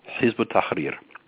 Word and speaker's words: Hizb ut-Tahrir: Well, the infiltration of Muslim Brotherhood, Al-Qaeda, a group hardly known and never discussed by our media called Hizb ut-Tahrir, which means the Party of Freedom. – How do you spell that? – H-I-Z-B, hyphen A-L, hyphen T-A-H-R-I-R, Hizb ut-Tahrir.